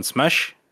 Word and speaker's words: Smesh: And uh, the question was thus: It was a question from Discord patron Smesh, S M E S H. It's kind of a phonetic spelling of how Habib Nurmagomedov pronounces the word Smash.